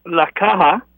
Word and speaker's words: Caja: But I bet you this La Hachina, how do you say it? – La Caja. – La Caja. – C-A-J-A, yeah. The okay, C-A-J.